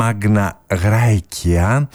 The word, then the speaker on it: Magna Graecia: Στα λατινικά να πούμε ότι προφέρεται ως Magna Graecia, ενώ στα ιταλικά προφέρεται ως Μάνια Γκρέτσια.